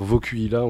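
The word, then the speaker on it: Vokuhila: Alors Vocuila, en fait, ça se prononce Vocuila, et c'est un mot allemand qui veut dire nuque longue.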